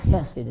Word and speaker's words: Hesed: It's actually Hesed. It's got a what we call the hard H in Hebrew, and it's spelled H-E-S-E-D, and it means the loyal love of God, the unchanging love of God.